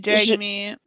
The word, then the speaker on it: Jagmeet: I just say Jagmeet.